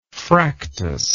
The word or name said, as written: fractus